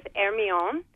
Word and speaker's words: Hermione: So I keep calling it Hermione because I'm American, but the real pronunciation, I'll let you do that and tell us about what and when is going to be happening with that boat. – Yeah, certainly. The pronunciation is Hermione, and it is a gorgeous replica frigate, which is coming, or it is here, actually.